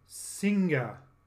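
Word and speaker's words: Singha: In the Thai language, Singa is pronounced Sing, despite its spelling.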